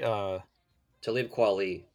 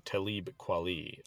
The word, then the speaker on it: Talib Kweli: Talib Kweli. – Thank you, Talib Kweli.